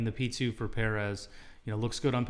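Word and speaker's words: Pérez: P2 for Perez, or Perez, as some listeners have reminded me that it's more accurately pronounced Perez. So P2 for Perez.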